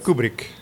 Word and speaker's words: Kubrick: Kubrick.